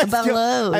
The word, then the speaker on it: balut: Hindi yung pag-pronounce ng bal- balot. Ah, balut. Ay, balut.